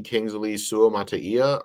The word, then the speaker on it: Kingsley Suamataia: Replacing Donovan Smith was the BYU rookie Kingsley Suamataia, I believe it's pronounced. – Yep.